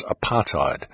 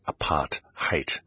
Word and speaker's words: apartheid: Many people know the word and many people pronounce it as apartheid, but the correct pronunciation of the word is apartheid.